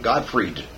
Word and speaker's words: Godfried: Not Godfrey, Godfried. – Oh, okay. – They call it Godfrey, it was Godfried.